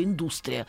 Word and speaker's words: индустрия: Или, к примеру, я всю жизнь говорила «индустрия», и не дали, как вчера вечером нас, наш уважаемый министр культуры, сказал «индустрия», но, оказывается, правильно говорит только «индустрия».